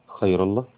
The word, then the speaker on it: خير الله: قال فيه المؤلف: محمد عبده حسن خير الله، الكي اتش هنا تنطق خاء يعني، was born in 1849 in a village in lower Egypt called محلة ناصر in the governorate of بحيرة.